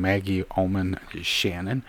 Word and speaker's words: Maggie Oman Shannon: It's written by Reverend Maggie Oman Shannon.